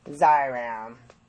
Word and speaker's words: Xyrem: And they say that, and the brand name is called Xyram. That's how you can find it. – Xyram. – Yeah, it's pronounced. Have to get a prescription for it. – Yeah, it's XYREM, Xyram.